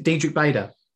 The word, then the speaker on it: Diedrich Bader: But there was a period of time where the actor's name is it Diedrich Bader. – Yes, that's correct pronunciation for his name.